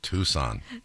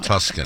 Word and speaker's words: Tucson: Tucson. Not Tuscan. – That's not how it's spelled. – Arizona. It's Tucson, Tuscan. Arizona. – Right, it's close, but not close.